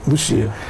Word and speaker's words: Лусия: Ведь пастушки и сестра Лусия, или Лусия, как ее правильно произносить? – Лусия.